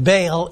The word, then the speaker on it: Baal: The way that it's spelled B-A-A-L, usually we pronounce it Baal in English, but the Hebrews, when they pronounce it, they split it up into two syllables.